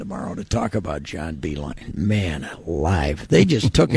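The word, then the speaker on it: Beilein: John Beeline.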